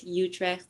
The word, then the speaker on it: Utrecht: My favorite city is Utrecht, uh, like Utrecht, if you want to pronounce it in English.